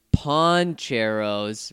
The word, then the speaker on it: Pancheros: He says, also, I know you guys have been getting a lot of recommendations about poncheros. Apparently, I've been saying it wrong. Surprise. – I've been saying pancheros, too. – I mean, come on. How else are you supposed to say it? It's P-A-N. – Well, P-A-N in Spanish is pan.